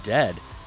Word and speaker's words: Dehd: Now after that, we had a very surfy tune from Dead, and that tune was called Lucky. Of course, that's spelled D-E-H-D if you're looking for the band.